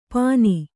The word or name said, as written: ಪನಿ